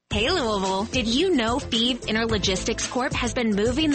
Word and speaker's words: Fives: Thieves is spelled F-I-V-E-S, like the number, but pronounced Thieves.